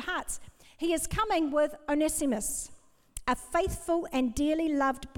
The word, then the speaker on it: Onesimus: Now, I looked this up, and I listened to how to pronounce these names, Tychicus and Onesimus.